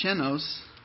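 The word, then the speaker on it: chenos (ξένος): You know, if you look up the word stranger in Greek, it's pronounced chenos.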